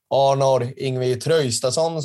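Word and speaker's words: Arnór Ingvi Traustason: Arnór Yngvi Traustason, som man ska uttala hans namn tydligen.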